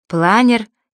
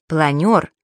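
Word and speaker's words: планёр: Если бы ее писали, то не было бы таких слов-уродцев, как «свекла», «планер» и «маневры». Ведь правильно «свекла», «планер» и «маневры».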